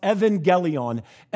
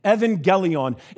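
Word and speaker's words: evangelion (εὐαγγέλιον): The first Greek word is pronounced evangelion, evangelion.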